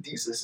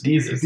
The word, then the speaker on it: Desus: Desus. – Desus.